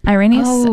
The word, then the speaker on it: Irenaeus: Saint Irenaeus. Irenaeus? Yes.